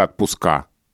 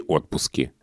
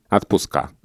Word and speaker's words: отпуска: И здесь э, правильно только отпуска. С таким ударением не отпуски, отпуска.